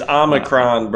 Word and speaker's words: Omicron: It's an O. It's Omicron, brother.